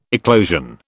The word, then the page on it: eclosion: British English